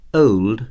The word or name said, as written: old